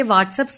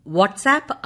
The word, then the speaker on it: WhatsApp: ഞാൻ കൃത്യമായി എന്റെ വാട്സ്ആപ്പ് സ്റ്റാറ്റസ് അപ്ഡേറ്റ് ചെയ്യാറുണ്ട്. നല്ല കാര്യം. എന്നാൽ ഉച്ചാരണത്തിൽ പിശകുണ്ട്. WHATSAPP വാട്സ്ആപ്പ് ആണ്, വാട്സ്ആപ്പ് അല്ല.